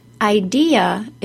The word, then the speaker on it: idea: First, the word is three syllables long. I D ya idea. If you're saying it as two syllables, it will be very hard for people to understand what word you're saying. So repeat the word after me to notice all three syllables idea.